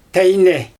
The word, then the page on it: tejne: Xyzyl